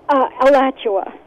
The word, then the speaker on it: Alachua: Florida, in Alachua, Florida. Am I saying that right? – Alachua.